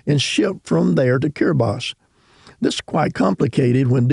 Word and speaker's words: Kiribati: It's spelled K-I-R-I-B-A-T-I. Looks like Kiribati, but it's pronounced Kiribati.